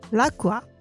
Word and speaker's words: LaQua: L'Acqua? – Yeah. That's just spa, and L'Acqua is spelled L-A-Q-U-A.